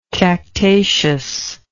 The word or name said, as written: cactaceous